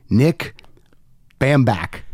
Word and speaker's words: Bambach: Sitting across the table from me, live, in person, I assume vaxxed and boosted, please welcome podcaster in his own right, Nick Bamback.